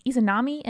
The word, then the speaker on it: Izanami: And this is episode 95 Izanami and Izanagi.